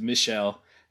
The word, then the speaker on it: Michel: and then the next day heard it pronounced correctly, which is Michel. And I'm like, oh, of course, he's French.